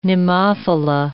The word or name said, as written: nemophila